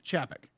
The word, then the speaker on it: Chapek: From Bob Iger to Bob Chapek. I might be mispronouncing his name. – Chapek, I think. Huh? But I think it's Chapek, but don't quote me. – Thank you. Chapek.